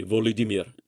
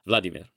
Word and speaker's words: Volodymyr: You're going to be Mikhailo, or you're going to be Volodymyr instead of Vladimir, or you're going to be Ihor instead of Igor.